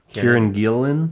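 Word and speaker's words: Kieron Gillen: Kieran Gillen? – Gillen. – Yeah. – Yeah. – That's how you pronounce it, actually. It's Kieran Gillen? – I forgot to write it down. – It's Welsh.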